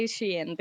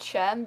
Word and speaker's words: Shein: Everyone says it like that, but I say Shein because I... – Yeah, I think that's the real way to pronounce it, but I just don't care.